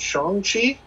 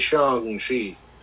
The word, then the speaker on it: Shang-Chi: I also read in their little thing, they said it's pronounced like song, not sang. – Yeah. – Is it? Yeah. Are we going to call it Shang-Chi? – Well, we are.